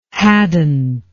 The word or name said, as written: hadnt